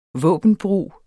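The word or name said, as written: våbenbrug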